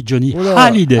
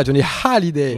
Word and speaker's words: Johnny Hallyday: Hallyday. – Mais tu le prononces mal, t'as pas entendu qu'elle dit Johnny Hallyday. – Ah, Johnny Hallyday.